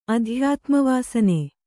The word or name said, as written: ಅಧ್ಯಾತ್ಮವಾಸನೆ